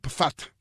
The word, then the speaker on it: Pfaadt: Eso es, se escribe P-F-A-A-D-T. Brandon Fatt, para que ustedes sepan.